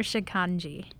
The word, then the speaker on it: Shikhanji: Chai or shikhanji? – Chai. – Did I say it right? – Shikhanji. – Shikhanji. – You're very close.